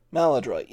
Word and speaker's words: Maladroit: Maladroit, yeah.